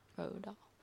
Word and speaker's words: Roald Dahl: Roald Dahl. Roald Dahl. – Roald Dahl. – Roald Dahl.